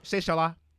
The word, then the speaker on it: Shola: I'm used to saying Shola for the Americans, but the way my name is pronounced is Shola.